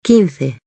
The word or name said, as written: 15